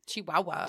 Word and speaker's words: Chihuahua: Is that the right way to say it? – Chihuahua. Chihuahua. That's how you said it.